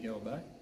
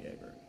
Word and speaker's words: Åberg: My last name is a little bit different too, because the letter is different. There's just a little circle above the A, which pronounces a little bit different. So in Swedish, I would say Ludvig Åberg, but here it goes Ludvig Åberg.